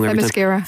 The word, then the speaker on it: Themyscira: The tone shift from Themyscira. Am I saying that wrong? – Themyscira.